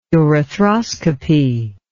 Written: urethroscopy

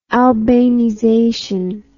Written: albanization

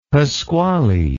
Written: pasquale

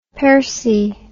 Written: persil

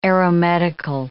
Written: aeromedical

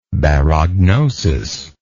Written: barognosis

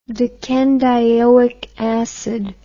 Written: decanedioic acid

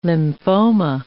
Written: lymphoma

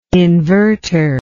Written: invertor

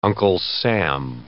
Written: Uncle Sam

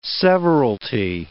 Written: severalty

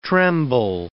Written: tremble

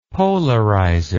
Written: polarizer

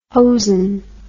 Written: hosen

